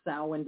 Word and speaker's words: Samhain: The Celtic festival Samhain is believed to be the predecessor of our contemporary holiday Halloween. And yes, it's pronounced Samhain despite that spelling.